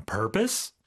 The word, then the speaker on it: purpose: 자, 이거 purpose라고는 발음하지 않습니다. – Right. – P-O-S-E로 끝나긴 하지만 비록 이걸 purpose라고 하면 안 되고요. 우리가 propose 할 적에는 ose라고 하는데 purpose 같은 경우는 강세가 앞에 오기 때문에 어떻게 할까요? – purpose.